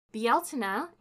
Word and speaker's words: Bealtaine: Bialtina is the Irish word for the month of May, and the word Irish pagans used for the celebration of May 1st. It is spelled Bealtaine, but others may be more familiar with its anglicized version, Beltane, spelled Beltane.